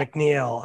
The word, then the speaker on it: McNeill: Did I say it wrong? McNeill. Sorry. – Yeah, yeah. McNeill.